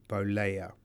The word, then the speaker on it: Bollea: And then Hulk Hogan, his real name is Terry Bollea.